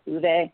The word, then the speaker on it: Ove: Some people pronounce it, but I believe it's Uwe. And this is called Anxious People, and another kind of perfect book for right now.